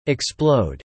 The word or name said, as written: explode